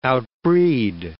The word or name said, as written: outbreed